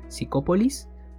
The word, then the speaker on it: Psicopolis: Vuelvo a repetirlo, se llama Psicopolis, o sea, se escribe con P al principio, Psicopolis, eh, y lo pueden encontrar en YouTube. Así, Psicopolis, cortometraje, y lo encuentran.